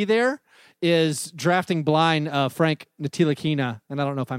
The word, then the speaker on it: Ntilikina: and they were talking about Ntilikina. It's a silent T, which I always just go to silent N, but it's a silent T.